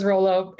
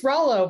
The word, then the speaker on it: Rollo: Everyone says Rolo. It's Rollo, but everyone says Rolo.